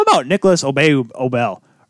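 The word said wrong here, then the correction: Aubé-Kubel: Or is it, how do you say it again? Kubel. Excuse me, Obey-Kubel.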